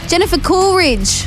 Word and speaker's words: Jennifer Coolidge: Jennifer Coolidge.